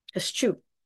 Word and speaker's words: eschew: And that's spelled E-S-C-H-E-W, eschew. Oh, eschew, I just sneezed.